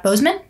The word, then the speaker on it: Boseman: Chadwick Boseman? – Yeah. – Boseman? – Yeah.